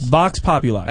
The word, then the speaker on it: Vox Populi: Vox Populi? – Yes. Four syllables. That's all. Only used four syllables. – Vox Populi.